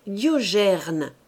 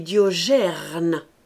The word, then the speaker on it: Diogène: Je dis à Rogier, qui est là, qu'il ne doit pas dire Diogène, mais Diogerne. Pas gêne, gerne.